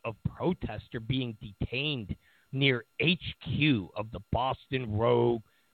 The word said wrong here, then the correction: Baton Rouge: Police Department. Baton Rouge Police Department. I hate when I mispronounce stuff.